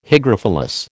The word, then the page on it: hygrophilous: American English